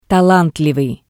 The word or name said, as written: talantlivii